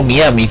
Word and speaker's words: Miami: Hoy nos tocó Miami, o Miami, como en realidad se pronuncia,